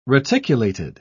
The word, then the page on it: reticulated: British English